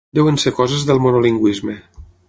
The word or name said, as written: coses